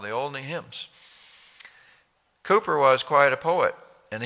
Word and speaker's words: Cowper: In your hymn book, you have one hymn by a man named William Cooper. You spell it C-O-W-P-E-R.